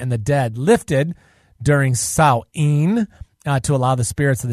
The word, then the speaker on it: Samhain: It was the original Halloween alternative, and then it kind of came up against the pagan Samhain festival, which is pronounced Sal-en, really, if you're going to do it correctly, celebrated the final harvest, death, and the onset of winter for 3 days.